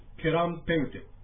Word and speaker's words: ケランペウテク: これも実はクエランペウテクというアイヌ語がつながりまして、ケランペウテク。というふうに発音します。それでは発音していただきます。ケランペウテク。 – ケランペウテク。 – ケランペウテク。 – はい。このケランペウテクは、 M の発音が入ってますので、口を閉じるようにしてやっていただくと、正しい発音になります。